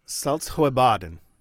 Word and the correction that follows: Saltsjöbaden: would you pronounce it? – Uh, Saltsjöbaden. – Okay, so you so get rid of the, the uh, no T in there.